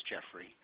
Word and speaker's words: Geoffrey: First name is spelled G-E-O-F-F-R-E-Y. That's Jeffrey. – G-E-O-F-F-E-R-Y? – R-E-Y. – R-E-Y.